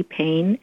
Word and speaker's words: Payne: Payne, and my last name is spelled P-A-Y-N-E, first name N-I-N-A, and .com.